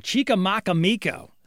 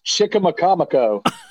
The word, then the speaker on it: Chicamacomico: I'm going to probably say this wrong. Chicamacomico. Is that right? – No, not even close. It's Chicamacomico.